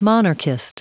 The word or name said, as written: monarchist